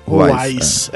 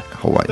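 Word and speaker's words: Houaiss: Como é que fala o nome do dicionário? acho que é Ruais que fala mesmo, né? – Ruais. É. é. – Ruais.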